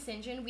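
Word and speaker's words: St John: We're saying Sinjin. We mean Saint John. It's pronounced Sinjin.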